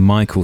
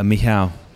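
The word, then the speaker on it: Michał: Because I'm very conscious I've been calling him Michael throughout the pod, which is incorrect.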